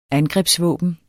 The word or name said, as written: angrebsvåben